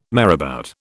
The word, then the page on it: marabout: British English